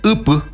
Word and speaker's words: upu: Bueno, ahí va a ser upu. Pues solo así nomás, pero que también es, no es monosilábico, es decir u y pu, dos sílabas.